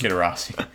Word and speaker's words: Kidderosity: Kidderosity. I'll butcher that to him. Makes sense. Like generosity.